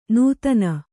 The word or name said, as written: ನೂತ್ನ